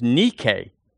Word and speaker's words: Nike: It's the word Nike. It's not pronounced that way. It's actually in the Greek. It's Nike, but it's N-I-K-E.